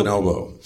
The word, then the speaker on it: bonobo: Bonobo? – Bonobo.